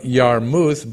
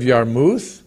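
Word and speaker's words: Jarmuth: The king of Yarmuth, by the way, in Hebrew, the J is pronounced like a Y. The king of Yarmuth, one.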